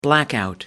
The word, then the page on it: blackout: American English